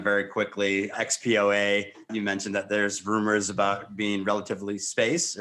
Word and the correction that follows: Relativity Space: or I probably mispronounced that. – Yeah, relativity space.